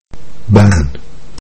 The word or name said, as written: ban